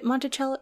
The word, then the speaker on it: Monticello: is it Monticello? Yeah, I think it's pronounced that way. Illinois has a town called Monticello, because we will not pronounce it the Italian way.